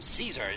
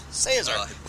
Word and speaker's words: Caesar: The only fault I have to find with your rendition there, apart from not having a tune to it, is that you used the effete pronunciation Caesar instead of Caesar, which rhymes with gaze, sir.